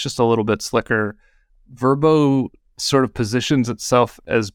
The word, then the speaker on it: Vrbo: I was interested in this last year, and so I did a direct comparison between Airbnb and Verbo, which is how they like us to pronounce it now.